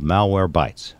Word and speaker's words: Malwarebytes: And now he's fighting to keep the world safe from hackers at a leading antivirus company called Malwarebytes. Did I pronounce it right this time? – That's correct. It's one word.